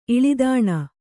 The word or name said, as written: ಇಳಿದಾಣ